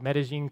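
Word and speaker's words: Medellín: I a few years ago, spent some time after college down in a city called Medellin, Colombia. And yes, I pronounced that correctly.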